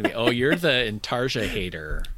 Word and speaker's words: intarsia: Intarsia, I believe it's pronounced.